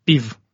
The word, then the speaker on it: Piv: It's Piv, short from Piva. – Okay. – I mean, beer on Russian.